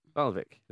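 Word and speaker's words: Waalwijk: A club I can't really pronounce very well, RKC Valvik. – Valvik, isn't it? – Thanks.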